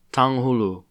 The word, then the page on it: tanghulu: American English